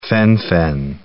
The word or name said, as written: fen-phen